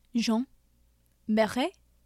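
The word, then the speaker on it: Jeanne Baret: Do you want me to pronounce it for you? – It's Jean Beret, I know, I think. Jean Beret. – Jean Beret? – One more time. – I think there's like a little like. – Can you get closer to the mic and one more one? – Jean Beret? – I think good. That's how you do it.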